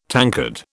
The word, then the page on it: tankard: British English